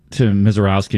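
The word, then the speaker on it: Misiorowski: I want to go back to Mizorowski, and you said it right.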